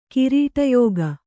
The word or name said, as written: ಕಿರೀಟಯೋಗ